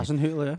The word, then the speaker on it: Hasenhüttl: Well, particularly under Hasenhüttl, I still can't say his name. But I think that's how you say it. – Hasenhüttl, yeah. Nailed it.